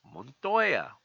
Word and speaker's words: Montoya: My name is David Montoya. Emphasis on the Montoya part. That's how it's actually pronounced. Montoya. Why? Because I am Hispanic.